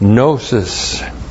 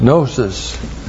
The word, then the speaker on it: gnosis: And then we have gnosis. G-N long O-S-I-S. Gnosis.